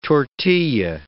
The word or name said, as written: tortilla